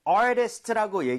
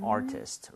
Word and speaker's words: artist: Artist, right? 하지만 빠르게 읽게 되면 T가 flap 현상이라고 해서 리을 비슷하게 발음이 될 수 있어요. So, 부드럽게 try만 해볼게요. Artist보다 artist인 것처럼.